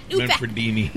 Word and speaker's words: Manfredini: Harry Manfredi. – Manfredini. – Manfredini. He told me how to pronounce that so many times.